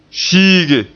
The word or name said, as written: fiige